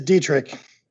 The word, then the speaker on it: Dietrich: Dietrich, did I, now that I've introduced you, did I get that wrong? Is it Dietrich? – It is Dietrich.